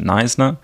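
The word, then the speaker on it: Knysna: Also, wie Jasmin schon gesagt hat, ich komme ursprünglich aus Südafrika, aus einem kleinen Dorf, es nennt sich Neisner. Sehr komisch geschrieben, einfach ausgesprochen.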